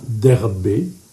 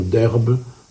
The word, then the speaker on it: Derbé: ce qui les conduit désormais à Derbé, comme ça qu'il faut le prononcer. Or, certaines versions ont Derbe, d'autres ont Derbé, qui est plus proche du grec.